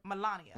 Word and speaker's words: Melania: And when, how do you say, her Melania? – Name? Melania.